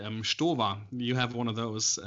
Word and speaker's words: Stowa: Not Stowa. – Because if you have an ST in German, it basically is pronounced as SHT, right?